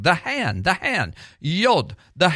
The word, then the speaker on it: Yod: Yod means hand, but Yod is how you pronounce that first letter, Yod.